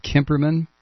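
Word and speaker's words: Cimperman: I believe that's how you pronounce it. It's C-I-M-P-E-R-M-A-N, Kimperman.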